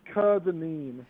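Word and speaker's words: Kerr-Dineen: It's by the book, it's Kerdeman. So, like, kind of, yeah, I don't know what it, the problem is, it doesn't sound like anything.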